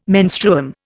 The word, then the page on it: menstruum: Indian